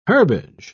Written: herbage

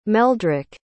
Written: meldryk

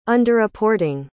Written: underreporting

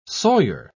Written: sawyer

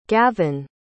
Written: gavyn

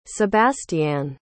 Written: sebastianne

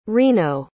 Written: reno

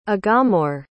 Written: aghamore

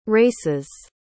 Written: races

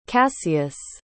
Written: kassius

